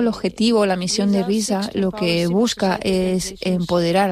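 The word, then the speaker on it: RIZA: Para nuestros oyentes, RISA con Z, aquí en Canarias pronunciamos mucho la S, para especificarlo.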